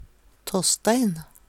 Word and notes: Tåsstein: Numedalsmål

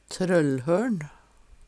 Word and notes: TrøllhøL`n: Numedalsmål

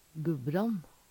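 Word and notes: Gubbrann: Numedalsmål; Veggli

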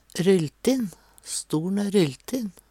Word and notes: rylltin: Numedalsmål

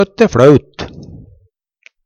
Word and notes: fLøt: Numedalsmål

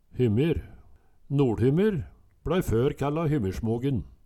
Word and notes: Hymmyr, NoLHymmyr: Numedalsmål

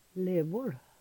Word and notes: LevoL: Numedalsmål

